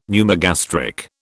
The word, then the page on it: pneumogastric: British English